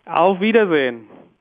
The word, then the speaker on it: Auf Wiedersehen: Auf Wiedersehen. – Auf Wiedersehen.